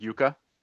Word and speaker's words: Jukka: Is it Jukka or, or with hard J or soft J? – Uh, I think it's a soft J. – It's like Spanish, like yuca. – Yeah. – Okay, so yuca.